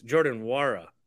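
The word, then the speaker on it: Jordan Nwora: So hopefully we can rest some more guys on Wednesday against the lowly Pacers, but they obviously destroyed us last time, and Jordan Nora scored 33 points yesterday. – I think you got his name wrong too. It's Jordan Wara.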